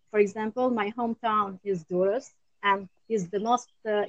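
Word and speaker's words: Durrës: Durres.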